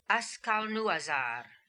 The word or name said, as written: Askalnooazar